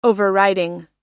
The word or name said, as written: overriding